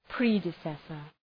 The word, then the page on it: predecessor: ‘predı,sesər